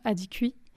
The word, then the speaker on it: Adikoui: Comme je disais, je prononce Adikou, ce nom dont, en fait, assez tôt dans le livre, elle va apprendre qu'il se prononce peut-être plus justement Adikoui.